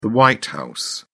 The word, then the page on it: white house: British English